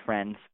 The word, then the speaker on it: Frenz: I just like the TV show Frenz, but yeah.